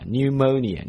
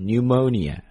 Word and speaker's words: pneumonia: 主要是怕担心呢你这个肺炎如果是传染你还出来来回的竞选你传给别人不好所以定性先定成叫 non-contagious, 然后第二个呢叫 bacterial, 是细菌性的 pneumonia, 注意一下这个 pneumonia 的发音。这个单词我再读一遍你再比一下它的这个字母的拼写 pneumonia, 你会发现它第一个 P 是一点音都没有的。第二一个 new, 你会发现那个 e 基本上不发音所以读的是直接是 n 和 u 的音 ,pneumonia, 表示肺炎。